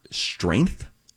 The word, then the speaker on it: strength: S-T-R-E-N-G-T-H. – How do you pronounce that word? – Is that right? – Yeah. – S-T-R-E-N-G-T-H. – Strength. Yes. – Strength. – Strength. – Strength. Strength. – And I say strength. – You pronounce the G? – I do. – Do you really? – Softly. Strength.